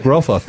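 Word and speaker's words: Ioan Gruffudd: It's like Groffeth.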